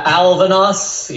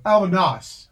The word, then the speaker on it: Alvanos: Uh, Alvanos. Yeah. Alvanos. Alvanos. Alvanos. – Alvanos. – Kind yeah, of, like albatross.